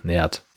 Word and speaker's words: Naird: Der spielt also General Nerd, Nerd, Nerd, N-A-I-R-D, Nerd spricht man ihn.